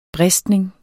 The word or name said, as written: bristning